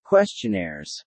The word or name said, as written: questionnaires